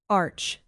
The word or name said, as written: arch